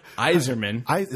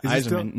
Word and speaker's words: Yzerman: Yzerman. – That's how you pronounce it. – Yeah, Yzerman.